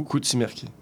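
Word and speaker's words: Kutsi Merki: Koutsimerki, K-U-T-S-I, espace M-E-R-K-I. Bon, le U se prononce plus comme un O, Koutsimerki.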